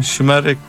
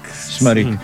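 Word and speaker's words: Şımarık: como é o caso do enorme êxito Simarik, Como é que é a pronúncia correta deste tema? – Schmarek. – Schmarek.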